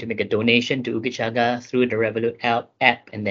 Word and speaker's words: Ooogachaga: So Ooogachaga is spelled as it is pronounced. So it's Ooogachaga, ugachaga.lgbt.